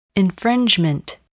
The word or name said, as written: infringement